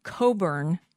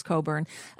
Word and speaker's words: Cockburn: Leslie Coburn, that's how you pronounce it. I know it's spelled Cockburn, but it's pronounced Coburn.